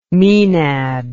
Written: menad

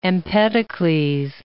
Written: Empedocles

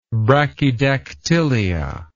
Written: brachydactylous

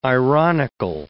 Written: ironical

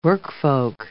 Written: workfolk

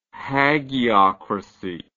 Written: hagiocracy

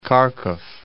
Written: Kharkiv

